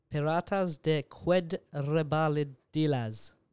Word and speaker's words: Piratas de Quebradillas: Piratas de Quebradillas. – That's a lot better than what I'd do. I saw the final word and thought quesadillas. But it's completely not that.